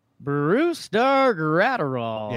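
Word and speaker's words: Brusdar Graterol: Brewstar Gratterall.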